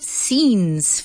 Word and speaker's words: scene: And now let's go back to love scenes. Fijaos que se escribe cene, pero no decimos no, it's scene, como si fuese S y larga N.